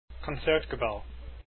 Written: concertgebouw

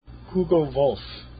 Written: Wolf

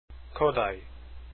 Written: kodaly

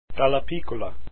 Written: dallapiccola

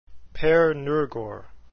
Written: noergaard

